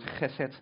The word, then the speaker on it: chesed: And maybe you've heard the Hebrew word chesed. It helps if you're Dutch that pronounce it.